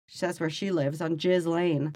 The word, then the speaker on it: Ghislaine: That's how you pronounce Ghislaine Maxwell. – No. – It's not Ghislaine. – It's Ghislaine.